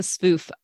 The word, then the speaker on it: Sfouf: I keep putting an extra syllable in there that I shouldn't be putting in. Okay. Well, thank you for the grilling tips. And, now about back to the Sfouf, the semolina flour, what does that add to the flavoring or the texture?